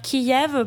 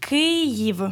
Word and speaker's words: Kyiv: Tout d'abord, il y a Kiev, qui ne se prononce pas d'ailleurs Kiev, parce que Kiev, c'est un nom qui vient de la langue russe. – Donc on prononce comment? – On prononce bien Kiev. – Kiev. – Kyiv. – Kyiv.